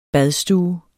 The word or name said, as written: badstue